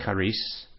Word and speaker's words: charis: The Greek word for grace in the New Testament, a little word study, it's number 5485, and it's CHARIS, pronounced charis, and it comes from 5463.